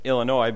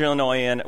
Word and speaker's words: Illinois: So this is maybe true for more than just Illinois, but you hate when people pronounce the S at the end of Illinois, right?